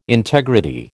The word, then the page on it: integrity: American English